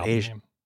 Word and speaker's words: Aja: It's pronounced like the continent? – I don't know. I mean, I could... If I talked to... literally anybody else I considered a friend, I would say, yes, just say it like Asia.